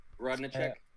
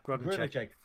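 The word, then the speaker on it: Grodénchik: How do we pronounce his surname again? – Grunichek. – Yeah, Grunichek.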